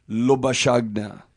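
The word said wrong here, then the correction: Labuschagne: Lubashagna? – Lubashane. – Lubashane.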